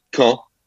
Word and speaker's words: Caen: I'm so happy to like pronounce this somewhat correctly. Caen. Caen. Because C-A-E-N in French is pronounced Caen.